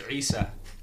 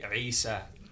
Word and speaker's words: Isa: In Arabic, it's Isa. I-S-A. – That's fire. Risa? – I-S-A. Risa. – Risa. – No, not the R.